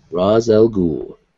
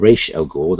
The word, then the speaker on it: Ra's al Ghul: They didn't show his face, but Ra's al Ghul. or Raish El Ghul, depending on your pronunciation, because I've heard it both ways.